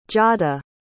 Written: giada

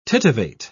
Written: titivate

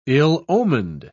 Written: ill-omened

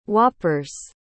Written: whoppers

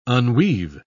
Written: unweave

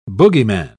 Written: boogeyman

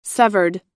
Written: severed

